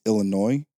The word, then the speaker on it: Illinois: And then after Michigan, it's Illinois. Because the S is silent.